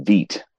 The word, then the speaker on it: Vite: So I have a good authority for Michael Bourne, but it's pronounced Veet.